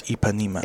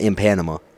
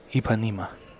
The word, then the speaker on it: Ipanema: I'm pretty sure it's Ipanema. – It's definitely not in Panama. – No, in Panama, Ipanema. But yeah, because in Brazil. – Oh. – Pronunciation. – Ipanema. – Yeah! – Ipanema. – Ipanema. – You were right.